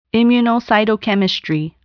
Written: immunocytochemistry

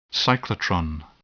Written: cyclotron

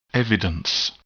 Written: evidence

